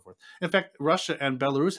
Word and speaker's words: Belarus: The same thing like he would say about the Belarusia. How do you say it in English? Belarus. Belarus.